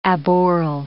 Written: aboral

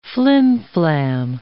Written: flimflam

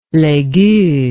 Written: Les Gueux

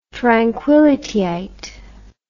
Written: tranquillityite

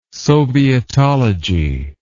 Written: sovietology

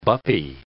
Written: buppie

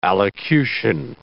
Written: allocution

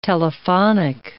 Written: telephonic

